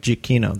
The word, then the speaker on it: Giacchino: But I think for what makes me so happy is that Michael Giacchino, oh my gosh, I always say his last name wrong. – Giacchino? – Giacchino, that's right.